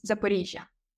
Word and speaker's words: Zaporizhzhia: So the right way to say is Zaporizhzhia.